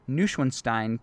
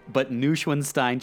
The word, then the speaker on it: Neuschwanstein: The two drew inspiration from a few castles in Europe, but they received most of their inspiration from Neuschwanstein Castle in Germany. I might not be pronouncing that right. – No, I think you killed that.